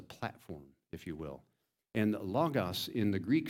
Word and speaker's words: logos: You could always tell the first-year seminary students because they said logos. But the proper pronunciation, just for if you want to appear slick, is logos, right? All right. Logos, that is the Greek word for word. Now, it's not word like the words I'm using to communicate with you.